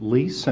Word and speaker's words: Leis: Yes, my name is David Leis, and my name is spelled D-A-V-I-D, and my last name is L-E-I-S.